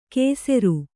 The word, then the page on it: ಕೇಸೆರು: kēseru